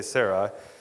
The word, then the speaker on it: Sarai: And it came to pass, when he was come near to enter in to Egypt that he said unto Sarai, and I probably will pronounce her name Sarah most of the time because I keep forgetting to say Sarai, said unto Sarai, his wife, behold now, I know that thou art a fair woman to look upon.